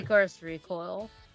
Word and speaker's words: Lycoris Recoil: I'm saying it wrong, you say it right. – Licorice Recoil.